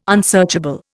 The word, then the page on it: unsearchable: Indian